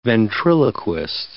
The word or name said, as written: ventriloquist